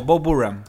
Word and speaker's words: Burnham: No, es como Burnham. – No suena la eche, estuve viendo. Es como Bo Burnham.